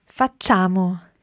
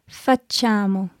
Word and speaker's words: Facciamo: Okay, although there's an I written down there, the I is just there to make the C soft. So you don't actually pronounce it. A lot of people say facciamo, but sì. It's not that. Facciamo.